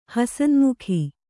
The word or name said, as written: ಹಸನ್ಮುಖಿ